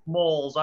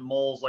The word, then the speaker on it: Molls: Molls. I pronounce it Molls, like mole in the ground, yeah.